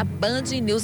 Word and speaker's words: BandNews: Meu filho, da Band News FM Manaíra. Eu tenho que estar atenta a tudo. Ah! – É, é, tá certa. Mas a pronúncia é Band News. Band News.